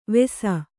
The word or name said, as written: ವೇಸ